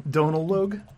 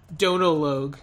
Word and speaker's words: Donal Logue: I went with, I'm going to pronounce this wrong, Donald Logue. – Oh, yeah. I believe it's Donal. Donal Logue? – Donal Logue.